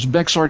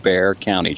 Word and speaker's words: Bexar: Hey, I heard a major news guy on a major news network over the weekend refer to Bexar County as Bexar County, but it's pronounced Bear, isn't it? – Yes, exactly right. – That's where I grew up. – He ain't from around here. – Yeah, that's right. That's where San Antonio is, and it is a funny spelling, but it is indeed pronounced Bear County.